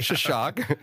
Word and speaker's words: Xoxoc: And you have a whole program, a project, the Shashak Project, which is spelled exactly as it sounds. Yeah. Shashak. X-O-X-O-C.